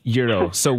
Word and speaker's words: gyros: gyro. So what is the proper pronunciation? – Yes, it's gyros.